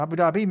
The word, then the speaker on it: Abu Dhabi: Abu Dhabi marathon.